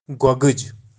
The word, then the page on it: gogij: Kashmiri